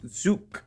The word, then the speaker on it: Zug: It's pronounced Zook.